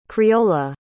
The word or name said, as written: creola